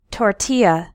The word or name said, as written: tortilla